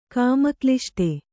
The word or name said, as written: ಕಾಮಕ್ಲಿಷ್ಟೆ